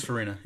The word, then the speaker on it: Farina: Yeah, no, Dennis Farina, yeah.